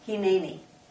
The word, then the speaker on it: Hineni: Hineni? – Hineni, yes. How do you say it? – Hineni. – Hineni. Hineni, yes.